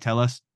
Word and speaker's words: TELUS: Am I saying that right? TELUS? – Yeah.